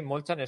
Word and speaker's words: Molchan: Djokovic se llevó su Belgrado 2 ganando a Alexander Molchan, que se pronuncia así, Molchan, es rollo como el sexto, un esloveno, 6-4, 6-3, en un partido...